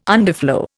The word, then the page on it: underflow: Indian